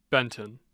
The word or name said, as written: benton